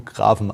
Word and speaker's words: Graphen: Nee, aber ich möchte damit sagen, also den Fehler, ganz ehrlich, also deutsche Sprache würdest du Graphen aussprechen.